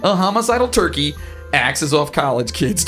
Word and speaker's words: Turkie: The villain's name is Turkey, but it's spelled T-U-R-K-I-E.